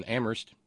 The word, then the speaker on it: Amherst: Our thanks go out to Renee in D.C., to Terry in Amherst, where only the H is silent,